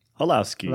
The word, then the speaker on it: Holloszy: This was Fitz and, I'm going to mispronounce this, but Holowski? – Holowski. – Holowski. H-O-L-L-O-S-Z-Y. Tell me how you pronounce that.